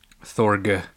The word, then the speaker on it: Thorg: And then we get to meet Thor. New Thorg. Contestant walks in. Thor. – Thorg. – Oh, Thorg. Sorry. – Yeah. Good old with a hard G.